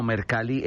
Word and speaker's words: Mercalli: Mercalli está bien. Digamos que por cuestiones, hay muchas similitudes en cuanto al el italiano con el español. Y Giuseppe Mercalli era justamente italiano y se puede decir, se puede pronunciar de esa manera, pues.